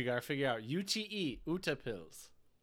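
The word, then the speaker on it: utepils: So the word is, I think the way you pronounce it is utapils. Utapils.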